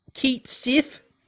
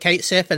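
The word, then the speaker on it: Cait Sith: We saw, I'm going to say his name wrong so many times because Keith Sif, I think they call it now that we actually got how it's said. I always used to call it Kate Sif, and it's always going to be Kate Sif in my head.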